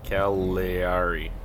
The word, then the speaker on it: Cagliari: So AC Milan will clinch with a W over Atalanta and a loss or a draw from Inter versus Calgary. But Calgary sits in 18th. – Wait, Calgary or Calgary? – I don't know how to pronounce it. – Cagliari. – Cagliari. Cagliari. – Cagliari. Yeah, come on. G-L-I.